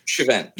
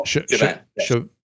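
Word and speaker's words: Shiven: Shivan. Yes. No. Not at all. Shivan.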